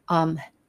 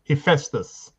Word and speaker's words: Hephaestus: Some people pronounce it Hephaestus. – Hephaestus, thank you.